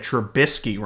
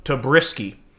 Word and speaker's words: Trubisky: but like this person said Trubisky wrong. – How they say it? – He said Tabrisky.